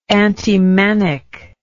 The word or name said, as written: antimanic